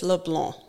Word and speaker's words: LeBlanc: So my husband is from Southern Louisiana, New Iberia, Louisiana, and it's LeBlanc. – LeBlanc. – No C.